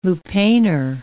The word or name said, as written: lupanar